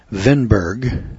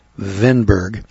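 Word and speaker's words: Kvenberg: One of the biggest challenges facing the group, according to the FDA's John Vinberg, and it's spelled K-V-E-N-B-E-R-G, I think the K is silent, John Vinberg, chairman of the Redefining Pasteurization Subcommittee, was the fact that new science has, or was emerging as the subcommittee was deliberating.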